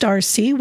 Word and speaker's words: RSSEE: The tentative name being used is the RSSEE school, pronounced RC, which stands for Richland Small School Educational Environment, focusing on the smaller class size opportunities for students.